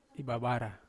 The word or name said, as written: bavara